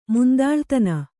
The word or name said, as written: ಮುಂದಾಳುತನ